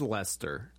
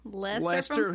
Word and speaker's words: Leicester: His name is Lester. – Yeah. – And he's from Florida. – Yeah. Yeah. – This is the most white trash name I've ever heard. – But it's spelled like a UK town. – Well, it's just, they're like, we don't want him to be white trash, so we're going to spell it fancy. – Yeah. – But it's Lester.